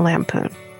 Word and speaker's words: Lampoon: And the National Oompa A Christmas Vacation. – National Lampoon.